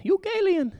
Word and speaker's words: euangelion: So when I did and the word euangelion came up, I actually had to Google, how do you pronounce euangelion? And I got the nice little Siri voice that said, euangelion. And so I am relatively certain that we're pronouncing that correct this morning.